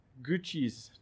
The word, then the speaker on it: Gucci: or they get a whole new wardrobe from Gucci's. Did I say it right? No, Gucci. What has it? I keep saying it wrong.